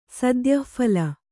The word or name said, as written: ಸದ್ಯಃಫಲ